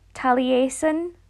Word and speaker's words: Taliesin: Taliesin is the way I usually hear that pronounced. Again, a Welsh person might correct me. I'm not completely strong on my Welsh pronunciation.